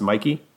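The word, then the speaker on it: Michie: Is it Mikey or it's spelled Mitchy, but I think it's pronounced Mikey.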